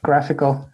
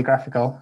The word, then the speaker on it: GraphQL: One other tool that you mentioned on your post and that we use a lot here at ZocDoc is the Graphical. I don't know how to pronounce that. GraphQL with an I in the middle. – Yep. Graphical. I call it Graphical. I'm not sure if that's a recommended way to pronounce it.